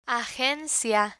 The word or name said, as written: agencia